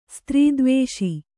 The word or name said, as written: ಸ್ತ್ರೀದ್ವೇಷಿ